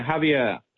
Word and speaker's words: Javier: Is that how you pronounce his name? – Yeah, Javier. Yeah.